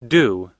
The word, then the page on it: due: long-u